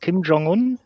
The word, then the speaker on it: Kim Jong-un: Und wie spricht man ihn korrekt aus? Kim Jong-un? Ist das schon so ja. ungefähr? Schon. Ja.